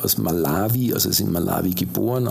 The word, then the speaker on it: Malawi: Also Malawi spricht man es eigentlich aus.